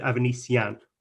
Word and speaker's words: Avanesyan: David Avanesyan, not Avanesyan, not Avanesyan, David Avanesyan. And I'm told that I've got that right, hopefully.